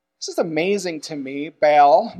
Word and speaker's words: Baal: So we say in English, Baal. Okay, a little side note here. In Hebrew, it's Baal. But it sounds like we're bawling something out of our throat, so we don't say it like that.